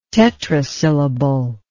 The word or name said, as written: tetrasyllable